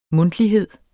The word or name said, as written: mundtlighed